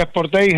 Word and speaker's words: Sportage: Dacia Spring eléctrico, Citroën C3, Kia Sportage, el Ford Tourneo, ¿cómo se dice el Kia, que lo dije mal? – Kia Sportage GT-Line. – Vale, ahí está bien dicho.